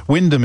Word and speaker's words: Wymondham: Wyndham is one of those curious English towns that's spelt entirely differently from how it is pronounced. It's spelt Wymondham.